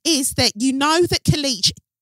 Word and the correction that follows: Kelechi: Kelechi, I heard you. I pronounced it this time, didn't I?